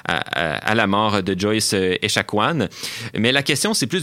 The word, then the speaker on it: Echaquan: Exactement, parce que ça aurait contribué à la mort de Joyce Etchemin. – Echaquan, pardon.